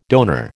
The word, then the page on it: donor: American English